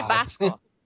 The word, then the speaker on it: Basco: And let's talk about Dante Briscoe. Oh, my God. – Dante Basco.